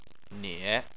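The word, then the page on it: nie: tone 3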